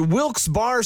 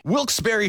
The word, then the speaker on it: Wilkes-Barre: And you're listening to Wilkes-Barre Scranton's 97.9X. Now, I had heard that it was supposed to be pronounced Wilkes-Barre. Wilkes-Barre Scranton's 97.9X. The only way to pronounce Wilkes-Barre.